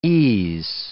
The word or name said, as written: es